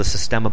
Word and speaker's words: Systema: I've seen other videos of the Systema stuff. I think that I'm pronouncing it correctly. It's system with an A.